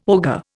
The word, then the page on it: ogre: Indian